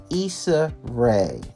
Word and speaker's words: Issa Rae: Created by actress Issa Rae, the playlist is part of the committee's efforts to have Americans participate from home for the inauguration.